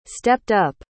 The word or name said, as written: stepped-up